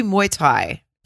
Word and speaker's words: Muay Thai: And she's like, I do Muay Thai. Did I say that right? – Yeah. Okay. Oh, I think so.